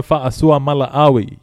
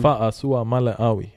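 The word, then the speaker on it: Fa'asuamaleaui: My big boy there, big Tino Fa'asua Mala'awi. You know, I've been practicing that pronunciation. Got the official confirmation for all my Samoans at work.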